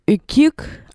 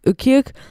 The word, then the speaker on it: Ukiuk: Then we listened to Ukiuk. I think that's how you say it. Based off listening to the lyrics, I think it's pronounced Ukiuk by Jerry Cans, released in 2016 off the album Inusik.